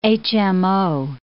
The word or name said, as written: HMO